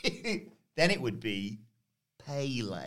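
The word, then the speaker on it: Pelé: Well, that's called an acute accent, which indicates to you that's different to a normal E. Therefore, you should pronounce it differently. So it's Pele.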